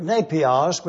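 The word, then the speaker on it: napios (νήπιος): It is the Greek word, napios, which means a nursing baby.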